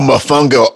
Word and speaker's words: mofongo: Is that mofongo, how it's said? – Yep. Oh, mofongo.